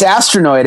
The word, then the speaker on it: Astronoid: Yeah, it's, it's Astronoid. It's exactly how it sounds.